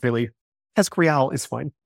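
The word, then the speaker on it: TESCREAL: How do we say it? – Teskriel. – There we go.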